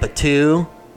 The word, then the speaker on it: Batuu: In the planet Batuu.